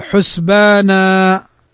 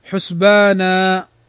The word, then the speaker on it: حسبانا: لو وقفنا على كلمة حسبانا فإننا ننطقها هكذا حسبانا، لا ننطق الفتحتين وإنما نقول حسبانا، فهذا يسمى مد العوض، ويمد بمقدار حركتين كما ذكر أهل العلم.